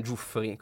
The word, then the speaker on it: Giuffre: Con un accento alla fine che non si capisce. Noi italiani Giuffre. Lo diciamo Giuffre. – Beh, Giuffre è il cognome italiano perché abbiamo appurato che Jimmy Giuffre discende da una famiglia siciliana, provincia di Palermo. – E ne siamo molto orgogliosi. – Però gli americani lo pronunciano Giuffri, quindi voi scegliete un po' la pronuncia preferita.